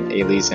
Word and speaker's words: Ailee: I like the fact that Ailee's in here. There's not much else I can say about it. – At least you're pronouncing Ailee right this time.